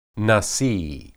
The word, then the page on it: nasi: Hebrew